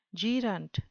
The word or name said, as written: gerent